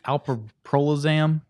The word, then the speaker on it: alprazolam: That how did you say it? Pete, alprazolam? – Yes.